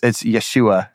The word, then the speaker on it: Yeshua: But it's Yeshua.